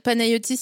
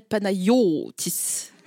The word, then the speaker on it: Panayotis: Est-ce qu'il y a un accent tonique sur ton prénom à un moment donné? – Non. Non. J'ai jamais su ce que c'était qu'un accent tonique, c'est marqué le truc. – C'est genre, si je t'appelle Panayotis, il n'y a pas d'accent tonique.